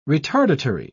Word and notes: retardatory: British English

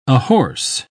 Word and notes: ahorse: British English

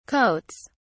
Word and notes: coats: British English

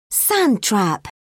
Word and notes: sand trap: British English

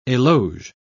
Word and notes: eloge: British English

